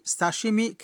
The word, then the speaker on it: 刺身: এই বাক্যের সঠিক অর্থ হলো, আপনি কি সাশিমি খেতে পারেন?